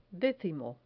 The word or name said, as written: decimo